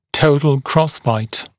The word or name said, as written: total crossbite